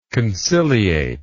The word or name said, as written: conciliatingly